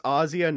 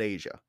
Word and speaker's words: Asia: So it's Ozzia, not Asia? – Yes.